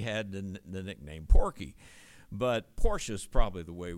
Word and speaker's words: Porcius: And by the way, in verse 27 of the previous chapter, I pronounced the name Porcius. That's how we would say it in English. But in Greek, it's actually a K, a kappa, rather than the C. And you would say Porcius.